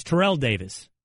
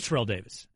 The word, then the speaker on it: Terrell: Man, see, Ter- Terrell Owens came in and threw off the pronunciation. Everybody. Yes. I agree. – Terrell Davis. It was always Terrell Davis until Owens came on.